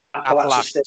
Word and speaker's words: Appalachian: I cannot pronounce this Apakayan State. Appalachian State.